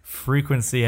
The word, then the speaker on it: FRE4KNC: Let me introduce you to the sounds of Frequency, but that's spelled FRE4KNC. But I looked at the pronunciation just to make sure I didn't sound like an asshole on air. It's pronounced Frequency, everybody.